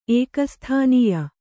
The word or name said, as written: ಏಕಸ್ಥಾನೀಯ